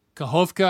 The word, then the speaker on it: Kakhovka: So you grew up in Kakhovka. I don't know if I pronounced that properly. – That was perfect.